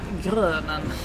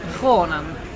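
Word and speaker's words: Groenen: Jag tror att jag har gått runt och sagt Grönen det senaste dygnet. Men Kronen, det är alltså så man uttalar svensk dödarens namn.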